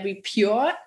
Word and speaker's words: Repure: denn du bist Mitgründerin und Projektmanagerin von der Repure App.